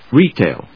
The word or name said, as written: retail